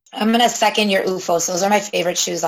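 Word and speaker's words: OOFOS: And I have recently acquired a pair of, and I'm going to mispronounce this, you might have to edit it, UFOS slippers. – Yes, that's right.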